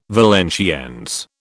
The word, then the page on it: Valenciennes: British English